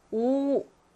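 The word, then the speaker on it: 오 (惡): 그래서 우리가 이때는 오, 악자를 미워하다 할 때는 오라고 읽습니다.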